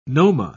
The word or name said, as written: noma